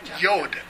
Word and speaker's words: yod: Yod. Y-O-D. And it comes across as jot in our versions. Okay. Yeah. It's pronounced yod. The D is real short.